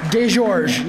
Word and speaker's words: Desgeorges: Desgeorges.